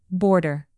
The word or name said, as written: border